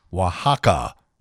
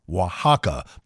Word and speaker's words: Oaxaca: Their top pick, a place in Mexico, pronounced Oaxaca, Oaxaca, but it's spelled O-A-X-A-C-A, and would have taken you all day to come up with the correct pronunciation on your own.